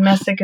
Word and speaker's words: Messick: Or I also have a business Facebook page called Morgan Messick Freelancing Services, and Messick is spelled M-E-S-S-I-C-K.